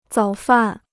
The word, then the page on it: 早饭: zǎo fàn